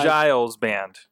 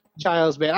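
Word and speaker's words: Geils: It's Giles band. – Giles band.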